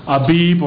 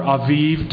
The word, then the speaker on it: Aviv: And the date is the first month of Nisan, which it changes, the name changes later in Israel's history, but Nisan and Abib or Aviv, depending on your pronunciation of that letter, is the first month of the Jewish calendar.